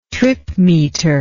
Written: tripmeter

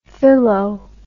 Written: filo

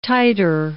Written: titre

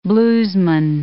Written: bluesman

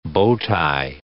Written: bow tie

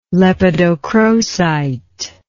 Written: lepidocrocite

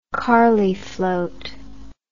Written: carley float

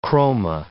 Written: chroma

